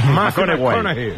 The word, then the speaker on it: McConaughey: ¿Matthew McConaughey? – Sí, sí, sí. Sí, sí. – Que hay que ir corrigiendo la pronunciación. – McConaughey.